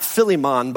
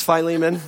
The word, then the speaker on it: Philemon: It is not Philemon, by the way. It's Philemon. In case any of you wonder, you've been like, I've been saying it wrong for years.